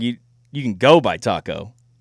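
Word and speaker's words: Tacko: Taco Fall? – Taco Fall.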